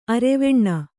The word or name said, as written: ಅರೆವೆಣ್ಣು